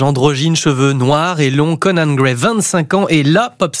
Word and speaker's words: Conan Gray: Vous voulez nous présenter un certain Conan Gray. Je prononce bien ? – Très bien.